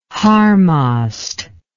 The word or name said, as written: harmost